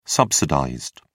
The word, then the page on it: subsidized: British English